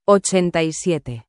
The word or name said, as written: 87